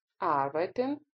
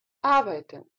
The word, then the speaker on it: arbeiten: wohnen – жить, denken – думать, heißen – называть, называться, lernen – учить, studieren – учиться, verdienen – зарабатывать, arbeiten – работать, kommen – приезжать, приходить, sprechen – говорить, speichern – сохранять, сберегать.